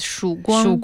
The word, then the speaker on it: Shuguang: Mm, I think it's named Shuguang, if I'm pronouncing it correctly. – In Chinese, it's Shuguang.